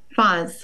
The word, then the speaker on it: Fahs: Fahs.